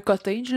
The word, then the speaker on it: cottage: Mais un cottage, là, je l'ai prononcé comme du fromage cottage. – C'est parfait. Hein? – Charles avait des problèmes de santé.